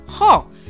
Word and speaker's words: ho: Das Problem ist, man muss nicht das H aussprechen. Zum Beispiel, io o, ich habe, man schreibt o nicht nur mit o, sondern auch mit ho am Anfang. Io ho. Aber die Italiener sprechen nicht das o aus.